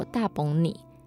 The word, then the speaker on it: 打幫你: 其实打邦尼，它在客语里面读作大崩你，大崩你就是客语的幸好有你，感恩的意思。